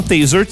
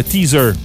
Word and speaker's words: Taser: Taser, T-A-S-E-R, tout le monde dit, comment est-ce qu'on le prononce en français? Le teaser. Les gens disent le teaser. Mais c'est ouais, comme le gun, là. – Mais c'est Taser, bref.